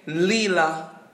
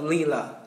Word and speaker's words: Lila: But she does say Lila and she calls herself Lila.